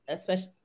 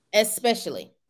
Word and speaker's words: especially: And I did get one thing, because again, I've been especially, man, how do you say the word? – Especially. ESP? – What is it? – Especially. – Especially. – Mm-hmm. – Especially. – Yeah, there's no X in it.